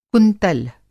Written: कुंतल

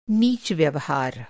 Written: नीच

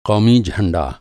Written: क़ौमी

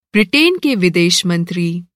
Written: ब्रिटेन

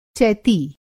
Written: चैती

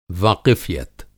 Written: वाक़िफ़ियत

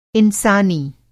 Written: इंसानी

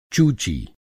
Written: चूची

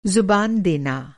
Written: ज़ुबान

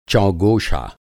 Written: चौगोशा

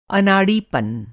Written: अनाड़ीपन

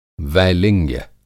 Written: वैलिंग्य

